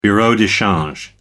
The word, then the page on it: bureau de change: American English